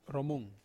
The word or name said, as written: romung